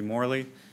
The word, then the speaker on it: Moehrle: May it please the court, my name is Matthew Morley. That's spelled M-O-E-H-R-L-E.